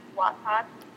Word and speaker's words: Wattpad: So is the correct pronunciation Wattpad? – Yes, it is. Because it's watt, like electricity, you know, the unit of electric measurement.